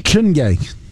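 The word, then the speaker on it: Chiney: Is it Chiny Okawike-Mike? – Chinye. Chinye. She's a Nigerian American.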